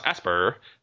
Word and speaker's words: Aspyr: As in, like Asperger's, but just the first. Aspyr. – Aspyr. – I guess. So Mac publishing house Aspyr may have accidentally revealed that Knights of the Old Republic...